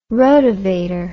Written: rotovator

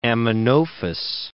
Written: Amenophis

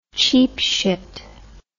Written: cheap shit

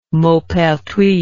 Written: maupertuis

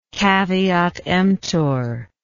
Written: caveat emptor

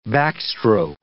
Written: backstroke

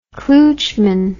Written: klootchman

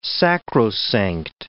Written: sacrosanct

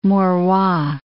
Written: Maurois

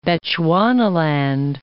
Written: Bechuanaland

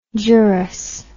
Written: gerous

